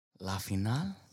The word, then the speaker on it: La finale: La finale? – La finale. – How do you say that in French? – Yeah. – Just like you said it.